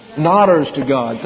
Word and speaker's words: nodders to God: Nods to Godders? Did I get it right? There you are. How do you say that? Nodders to God.